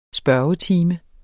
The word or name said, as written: spørgetime